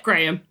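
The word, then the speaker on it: Graeme: Flying into Antarctica, it's G-R-A-E-M-E. – Graham? – Yeah, Graham.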